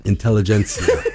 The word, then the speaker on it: Intelligentsia: Oh, that's how you pronounce that. – Intelligentsia. Just like the coffee.